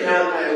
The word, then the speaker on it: Malachi: We see this in, speaking of the Italian prophet, I'll say it right, sign, Malachi. Everybody say Malachi with me on the count of three. One, two, three, Malachi. Just want to make sure that I haven't messed you up with the Italian pronunciation.